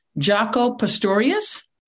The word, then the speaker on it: Jaco Pastorius: Tell us about, and tell me if I'm pronouncing this correctly, Jaco Pastorius? – Yeah.